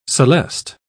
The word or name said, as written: celeste